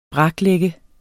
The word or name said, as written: braklægge